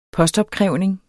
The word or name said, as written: postopkrævning